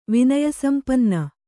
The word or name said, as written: ವಿನಯಸಂಪನ್ನ